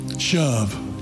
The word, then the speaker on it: shuv: 1,100 times in the Bible, the word S-H-U-V, the Hebrew word S-H-U-V, is listed there. Do you know how you pronounce it? Shove.